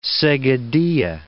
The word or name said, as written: seguidilla